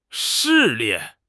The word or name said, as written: 视力